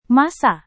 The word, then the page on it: massa: Indonesian